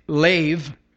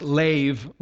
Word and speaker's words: lev: Now, if you look at the original language, the Hebrew language there, the word for heart is lev. It's transliterated in English, L-E-B, just L-E-B, but it's pronounced lev, like with a V.